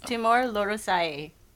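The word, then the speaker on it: Timor-Leste: Timor Leste? Am I saying that right? – It's Timor Leste.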